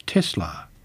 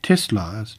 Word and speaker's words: Tesla: With the name taken from the Croatian-born Serbian-American inventor Nikolai Tesla, purists argue that the unvoiced S is the correct way to say it. This should mean pronouncing the company name as Tesla, whereas Tesla's CEO, Elon Musk, prefers to say Tesla instead.